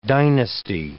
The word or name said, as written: dynasty